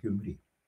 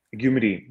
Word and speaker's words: Гюмри: Ударение на последнее слово, Гюмри. – Гюмри, да.